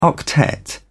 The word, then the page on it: octet: British English